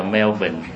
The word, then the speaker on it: Melbourne: Người ta viết là men buộc mà phải đọc là Melbourne.